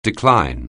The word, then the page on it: decline: anglais